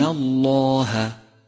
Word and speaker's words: الله: خامسا، لاحظ تفخيم لام لفظ الجلالة من كلمة إن الله إن الله، فخمت لأنها سبقت بحرف مفتوح، ولام لفظ الجلالة كما مر معنا تتبع ما قبلها في الترقيق والتفخيم.